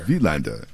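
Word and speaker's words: Wilander: Wielander.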